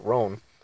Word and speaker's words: Rohn: I've always pronounced it Ron, but I've heard other people pronounce it Rohn. But Jim Rohn, that's R-O-H-N.